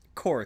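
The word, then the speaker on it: Corps: Core. It's a silent P, man.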